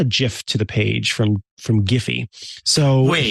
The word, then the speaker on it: Giphy: But you'll notice that I said Giphy because the creators of Giphy said we pronounce it Giphy.